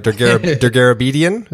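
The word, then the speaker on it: Dergarabedian: Now, make sure I'm saying this right. Daguerreobedian, is that right? – Perfect.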